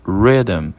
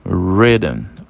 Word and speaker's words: rhythm: E a terceira palavra nós temos rhythm. Rhythm. Rhythm significa ritmo, tá? E mais uma vez, o H ele é silenciado aí depois do R nessa ocasião.